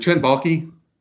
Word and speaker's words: Baalke: Is it Trent Baalke? – Yep.